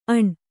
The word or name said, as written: ಅಣ್